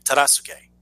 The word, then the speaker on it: Tadasuke: Tadasuke, right? Tadasuke. That's how you say it.